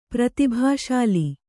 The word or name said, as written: ಪ್ರತಿಭಾಶಾಲಿ